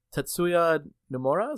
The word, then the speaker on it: Tetsuya Nomura: So series director Tetsuya Nomura, is that how you pronounce his name? – Nailed it. – Thank you.